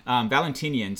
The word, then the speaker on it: Valentinian: After Valentinian, thank you. Thank you. Yeah, I might have wrote it down incorrectly because I kept looking at it and I couldn't say it correctly. Valentinian.